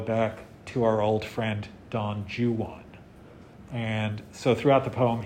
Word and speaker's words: Don Juan: By the way, it is pronounced Don Juan, not Don Juan, if you're reading Byron.